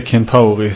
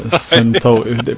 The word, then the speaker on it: Centauri: Och inte Kentauri som jag har hört folk uttala, utan Centauri.